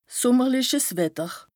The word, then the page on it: sùmmerliches Wetter: Bas-Rhin; Strasbourg